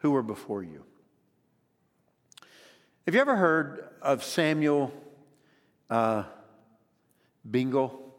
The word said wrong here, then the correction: Brengle: So, you ever have that moment where you think, I don't think I got his name right? Bringle. B-R-E-N-G-L-E, Bringle.